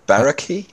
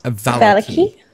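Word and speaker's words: Vallaki: And what's the town? Baraki? – Valaki.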